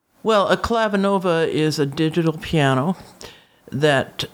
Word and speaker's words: Clavinova: Now, the concert that I remember you gave, and this, is it Clavinova? – Yes. – Am I saying that correctly? – You are.